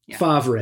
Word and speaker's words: Favre: Favre, exactly. Favre.